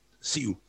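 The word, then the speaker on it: Si'u: So he's probably too polite to correct people, but Siu, that's how his name's pronounced.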